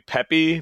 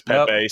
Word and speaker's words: Pepe: So it's technically Pepe, but everybody says Pepe, so I'm just like, yeah, whatever.